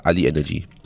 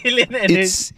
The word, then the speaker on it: AlienEnergy: Is it Ali Energy or yeah, Alien yeah. Energy? – Oh. – It's Alien Energy. – It's spelled AlienEnergy. So I thought it's Ali Energy because, you know, China, right? – Then because this is Alien. – Yeah, so it's like, oh, Alien Energy.